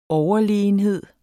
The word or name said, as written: overlegenhed